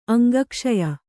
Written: ಅಂಗಕ್ಷಯ

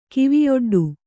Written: ಕಿವಿಯೊಡ್ಡು

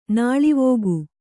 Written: ನಾಳಿವೋಗು

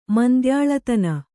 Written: ಮಂದ್ಯಾಳತನ

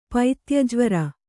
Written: ಪೈತ್ಯಜ್ವರ

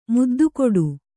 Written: ಮುದ್ದುಕೊಡು

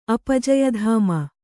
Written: ಅಪಜಯಧಾಮ

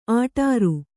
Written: ಆಟಾರು

